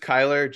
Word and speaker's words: Kyler: Yeah, it's just Kyler, just Kyler. Like okay. Tyler, just like awesome. Every other name.